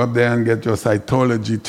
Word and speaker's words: cytology: Is that psychology? Cytology? Is that what it is? It cannot be psychology. It's cytology.